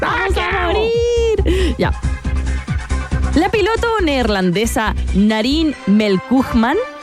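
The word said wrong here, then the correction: Melkumjan: No, lo pronuncio mal. Narin Melkuhan. Melkumhan, ahí está. La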